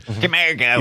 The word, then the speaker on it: Camargo: But then there's these guys like Christian Camargo. I never say it right. – Camargo. – He played Daniel, and he's familiar if you've seen the show C-S-E-E on Apple Plus.